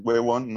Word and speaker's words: huevón: Nosotros en Colombia también usamos el huevón, que es huevón, no huevón. – huevón. – Este, este, tiene acento en la última O, para hacerlo más fuerte.